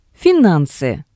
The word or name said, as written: finansi